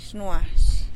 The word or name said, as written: xnuax